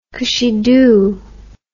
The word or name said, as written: cushiedoo